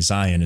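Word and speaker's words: Zeihan: Now, Peter Zion, spelled Zahan, basically, Zion is how he pronounces it, is somebody I've been following a bit.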